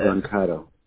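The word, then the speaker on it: Brancato: Broncato.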